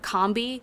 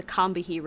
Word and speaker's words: Combahee: And I know that there are probably some people who live in, I think the river is in North Carolina and they pronounce it Combi. I recognize that that is how you say it when you're living in the South, but the collective referred to themselves as the Combahee River Collective, so I'm going with that.